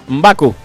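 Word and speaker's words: M'Baku: ¿Y qué te parece Mbaka? Así como te lo pronuncio. M, el guioncito, el apócrifo. – Mbaku. – Mbaku.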